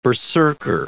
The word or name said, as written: berserker